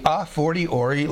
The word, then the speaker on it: a fortiori: And one of those points is called the a fortiori logic. I'll spell it for you. A, separate word, fortiori, F-O-R-T-I-O-R-I. A, second word, F-O-R-T-I-O-R-I, logic.